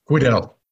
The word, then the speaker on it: Quidel: And before we get into the details of the deal, I always thought is it Quidel or is it Quittle? Do you have any insights on the pronunciation? – Quidel. – Quidel. Mm-hmm. All right. You were closer.